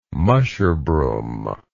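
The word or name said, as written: Masherbrum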